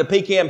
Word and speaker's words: pecan: And by the way, in case you're grammatically inclined and you're trying to correct me, it is pronounced pecan. There's no doubt about that. If you came here, you don't leave with anything else. I'm here to set this straight. It is a pecan.